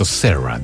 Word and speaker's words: Sarah: Escuchábamos una canción con nombre de mujer, Sara, ¿cierto? Sarah, dice la pronunciación en inglés, con la banda Starship, que ya les voy a comentar alguna cosa de ese nombre, tal Starship.